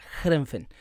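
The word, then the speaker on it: Heerenveen: un Wilke, que la está rompiendo en Holanda con el Herenfen. Herenfen. Jerenfen se pronuncia Gerenfen.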